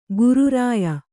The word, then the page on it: ಗುರುರಾಯ: Kannada